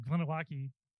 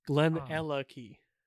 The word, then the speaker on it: Glenallachie: The Glenallachie. Did I get that right? – Not quite close enough, but Glenallachie. Uh, Glenallachie.